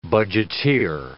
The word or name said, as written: budgeteer